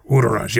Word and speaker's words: Wudaraj: Our first guest is a Sharn native, and his name is Wudaraj. Did I pronounce that correctly? – Wudaraj, yes.